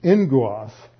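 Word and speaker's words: engos (ἔγγυος): A double gamma is always pronounced like an ng. So just like angel is really agge, but the gamma comes across as an ng. So it's engos.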